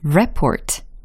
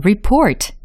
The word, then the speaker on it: report: 어, report 들으셨죠? report 라고 하면 보고하다 라는 동사적인 발음입니다. 그러면 report 라고 하면 보고서에 해당하는 명사적인 발음이 되겠죠. 아, report와 report. 아, 요 차이를 알고 계셔야지 제대로 발음을 하고 활용하실 수 있겠죠.